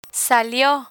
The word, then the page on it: salio: spanish